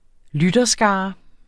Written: lytterskare

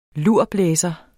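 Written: LURBLÆSERE

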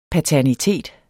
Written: paternitet

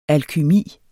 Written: alkymi

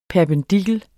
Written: PERPENDIKLEN

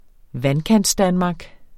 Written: Vandkantsdanmark

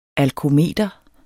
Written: alkometer

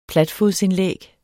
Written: platfodsindlæg

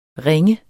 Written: ringe

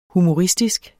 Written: HUMORISTISKE

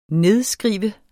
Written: nedskrive

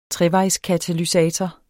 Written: trevejskatalysator

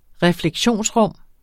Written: refleksionsrum

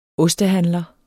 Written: ostehandler

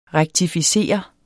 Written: REKTIFICERES

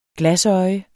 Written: glasøje

